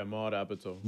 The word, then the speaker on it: Maude Apatow: Is that how you pronounce her name? Maude? – Yeah, Maude Apatow.